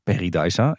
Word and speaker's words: Paradise: En die had een interview met eh, de marketingmanager van Paradise. En Danny stelde de vraag, hoe spreek je die naam nou eigenlijk uit? En het is dus echt Paradise.